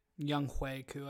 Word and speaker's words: Younghoe Koo: Younghoe Koo, I believe.